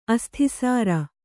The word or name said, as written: ಅಸ್ಥಿಸಾರ